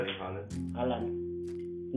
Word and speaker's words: Erling Haaland: Erling Haaland. Is that his name? Am I saying it right? – Erling Haaland.